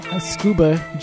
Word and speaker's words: Tsukuba: that was in honor of our friends from Tsukuba, Japan. And... I know it sounds like S-C-U-B-A, but it's spelled very differently. It has a T at the beginning.